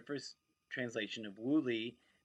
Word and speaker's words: Wu Li: with the same exact English spelling and each pronounced in English, Wu Li.